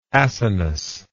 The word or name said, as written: acinic